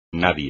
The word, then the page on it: nadie: espagnol